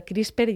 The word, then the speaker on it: CRISPR: que es esto del CRISPR y el CRISPR-Cas.